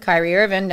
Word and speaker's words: Kyrie Irving: Kyrie Irving, not Tim.